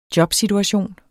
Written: jobsituation

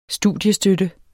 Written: studiestøtte

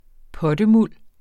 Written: pottemuld

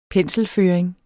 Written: penselføring

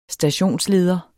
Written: stationsleder

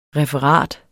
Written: referat

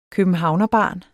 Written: københavnerbarn

